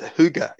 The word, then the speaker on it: Hygge: It's Hygge. Yeah, I think Hygge is the correct pronunciation.